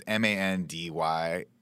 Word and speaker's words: Mandylor: Costas Mandalore, and he's Greek. – Mandalore, sick. – Mm-hmm. It's, yeah, I might be mispronouncing it. It's Mandylor.